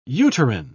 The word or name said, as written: uterine